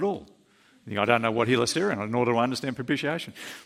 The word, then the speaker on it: hilasterion: It's the word hilasterion. That's how you pronounce that word there. That's hilasterion.